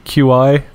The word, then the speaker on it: Qi: QI. I think it's pronounced Qi. Might be Qi charging? – Qi.